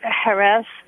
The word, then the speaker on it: Jerez: We've been having a lot of really interesting sherry since I got to this place, which is spelled J-E-R-E-Z. It's pronounced Jerez, or Jerez, depending on who you are.